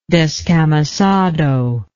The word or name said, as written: descamisado